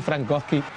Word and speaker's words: Frankowski: Hiszpańscy komentatorzy szybko nauczyli się w poprawny sposób wymawiać jego nazwisko. – Pero sí, Frankowski.